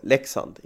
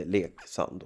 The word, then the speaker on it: Leksand: Men uttalet är ju då Leksand, inte Leksand.